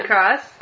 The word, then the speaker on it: La Crosse: 12 is University of Wisconsin, La Crosse. I think I'm saying that right. – La Crosse? – Mm-hmm. – No, yeah, that's right.